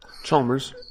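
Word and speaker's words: Allis-Chalmers: Alice Chalmers? How do you say that? Do you know what I'm trying to say? – Chalmers. – Chalmers? – You did it right.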